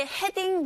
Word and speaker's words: heading: Every second heading to 뭐 is jolly 이렇게 발음하면 되는데 이때 heading 발음할 때 heading이 틀린 발음은 아니지만 우리 귀에 들릴 때는요. heading 거의 R 소리 가깝게 들리게 되는데 T 사운드가 강모음과 양모음 사이에서 르처럼 들리는 것과 똑같이 이 D 사운드 역시 모음 사이에서 이렇게 들려요. 르르 heading 이런 식으로 발음하시면 됩니다.